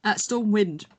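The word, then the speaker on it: Stormwynd: Stormwind. – Stormwind. – It's just wind, but spelled fantasy. – Yeah. So WYND. Stormwind.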